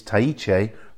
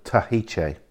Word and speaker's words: Tahiche: Towards the centre of the island, we have Tahiche, where the prison is, Tahiche. So the H almost disappears in that. It's Tahiche rather than Tahiche.